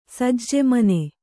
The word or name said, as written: ಸಜ್ಜೆಮನೆ